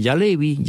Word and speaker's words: jalebi: Se llama Jalevi, no la película, sino Jalevi. Jalevi es el Jalevi, se escribe J-A-L-E-B-I, el Jalevi.